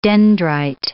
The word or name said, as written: dendrite